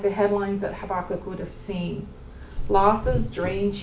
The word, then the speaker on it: Habakkuk: Habakkuk is how it's pronounced.